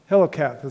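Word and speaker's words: Qoheleth: Hiloketh is how you say it in Hebrew.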